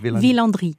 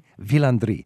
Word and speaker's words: Villandry: And I think Villandry is the, I'm not into gardens that much, but I'll tell you, the gardens at, how do we say it in French? – Villandry. – Villandry.